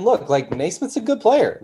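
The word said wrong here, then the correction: Nesmith: Like, or Neesmith, I guess, is how he's pronounced. I've literally always said Naismith,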